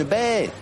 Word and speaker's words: baie: Ah, c'est baie. On dit baie, hein, c'est baie ailleurs. – Baie. – Une baie de Goji. – Goji, G-O-J. – Mais non, mais prononcez, parce que vous dites Bête Goji.